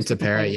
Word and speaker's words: Tepera: And Dusty, did you see the thing about Dusty not knowing who, is it Tepera? How do you say it's Tepera, right? Ryan Tepera's name. – Ryan Tepera, yeah.